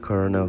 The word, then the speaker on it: colonel: Viu que a palavra col colonel se escreve com L? Gente, cada dia descobrindo coisas novas, né? Eu fui conferir, né? Eu não coloquei aqui no áudio, mas eu fui conferir porque eu falei, gente, será que é cola? Não. E aí quando eu fui ver, se escreve com L e fala e tem som de R.